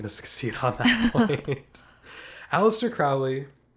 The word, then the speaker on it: Aleister: He abandoned Edward and changed Alexander to Aleister specifically because he heard that the most pleasing format for a name was the rhythm pattern that Aleister Crowley gives you. That like first syllable stress, three syllables, and then first syllable stress, two syllables.